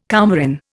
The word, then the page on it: coumarin: Indian